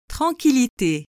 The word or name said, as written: tranquillite